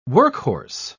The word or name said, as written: workhorse